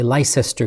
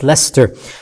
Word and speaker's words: Leicester: I would say Leicester because that's how it's spelt, and I made a big embarrassment out of myself when I visited the city and I mispronounced the name because it's spelt Leicester, but it is pronounced Leicester.